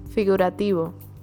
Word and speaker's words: figurativo: Figurativo? – Yes. – But say it. You say it. – Figurativo. – Figurativo.